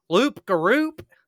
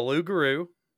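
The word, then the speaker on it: loup-garou: Loop Garoop. But it's called the Lugaroo, werewolf. – Um, You didn't pronounce a single one of those P's.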